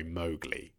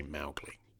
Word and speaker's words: Mowgli: Apparently, Rudyard Kipling's daughter was furious that in the Disney version they pronounced the name of the boy Mowgli because her father used to say Mowgli.